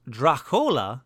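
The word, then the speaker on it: droch-fhola: from the Irish Drachfola, pronounced Drachola, meaning bad blood.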